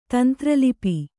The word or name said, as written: ತಂತ್ರಲಿಪಿ